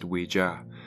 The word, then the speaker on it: Ouija: First up, it's actually thought to be pronounced Ouija, and it's entered popular culture as an otherworldly oracle and something entertaining that's both scary and exciting to use.